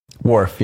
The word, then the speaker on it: Worf: So, and it was Worf. I'm saying that right. I'm not a Star... – Worf, yeah.